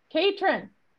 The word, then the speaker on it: Catron: David Catron, I just said it wrong. – Catron. – So I finally got my Catron. Catron. Right? – Catron. Like patron of the arts.